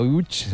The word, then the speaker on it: Łódź: Aunque se pronuncia Utch, se escribe Lodz, por si alguien lo busca.